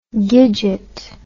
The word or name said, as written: gidget